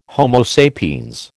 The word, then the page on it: Homo sapiens: American English